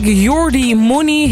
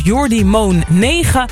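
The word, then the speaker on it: Jordymone9: Zo had ik Jordi Money heel lang uitgesproken als Jordi Moon 9.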